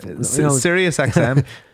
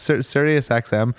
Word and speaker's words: Sirius XM: Yeah, is that how you pronounce it? – Would you want to hear something, right? – I don't want to pronounce it like a country that's all... – Sirius XM. It's Sirius XM.